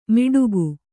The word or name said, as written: ಮಿಡುಕು